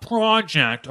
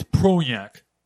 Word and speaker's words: Projekt: It's not project, okay? It's Projekt.